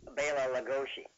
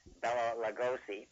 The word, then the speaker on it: Bela Lugosi: But that's the that's the correct pronunciation of his name. And very often, if you hear him at the beginning of a program, when he's introducing himself, uh, he will definitely put the accent on Bela.